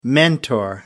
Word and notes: mentor: American English

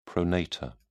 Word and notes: pronator: British English

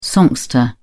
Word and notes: songster: British English

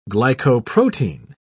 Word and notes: glycoprotein: British English

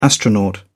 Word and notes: astronaut: British English

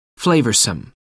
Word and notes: flavoursome: American English

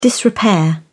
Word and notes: disrepair: British English

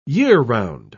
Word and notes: year-round: British English

